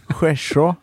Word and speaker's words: Skärså: Man skulle inte uttala Skärså. – Skärså. – Ja, helt riktigt.